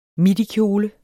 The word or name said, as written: midikjole